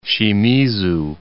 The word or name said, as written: Shimizu